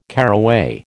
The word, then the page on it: caraway: American English